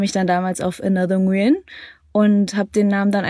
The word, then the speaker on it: Nguyen: Ich würde es Nguyen aussprechen. – Okay, guck, das könnte ich gar nicht, glaube ich. – Nguyen. Also einfach breiter, so Nguyen.